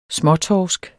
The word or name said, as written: småtorsk